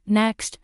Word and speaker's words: KNXT: According to the fashion law, the fashion conglomerate has filed a trademark application for KNXT, pronounced next for use on advertising, networking services, and telecommunication services in the field of fashion via the internet and other communication networks.